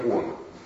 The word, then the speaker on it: Оно: Ударение на первое О. Во время Оно.